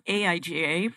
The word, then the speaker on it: AIGA: So, AIGA, pronounced like that, originally stood for the American Institute of Graphic Arts when it was founded over 100 years ago.